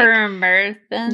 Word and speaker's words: permethrin: permethrin? – Yes, that one.